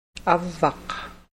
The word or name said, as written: avvaq